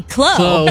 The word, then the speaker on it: Chloe: And he was like, "Yeah, it's spelled C-H-L-O-E, Chloe." And I'm like, "Yeah, that's Chloe, Dad."